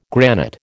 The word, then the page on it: granite: American English